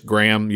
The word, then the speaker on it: Graham: It is just Graham. You don't ever pronounce the H either.